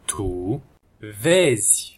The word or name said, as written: tu vezi